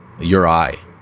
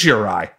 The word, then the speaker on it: Juraj: X-H-E-K-A-J. K-A-J. – Well, because like Slepkovsky on the same team, his name is Juraj, is it not? – Juraj. – It is Juraj. Okay, so yeah. That's just how, so I've just been pronouncing those wrong. I could have sworn that it was like a zh, but okay.